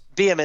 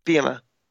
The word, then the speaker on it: Bielema: Brett Bielema. Bielema is how you say his name. – Bielema? I'm sorry. – Bielema.